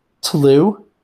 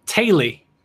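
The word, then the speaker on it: teulu: So finally, pronounce this Welsh word for me. I'm going to spell it for you. T-E-U-L-U. T-E-U-L-U. – Tlu? – It's a good guess. Taily. – Okay. – And it means family.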